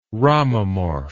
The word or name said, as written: ramamorph